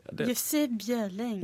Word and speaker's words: Jussi Bjørling: Vi skal ta og høre på O hellige natt med Jussi, Jussi Bjørling. Er det riktig uttalt hans? – Jussi Bjørling.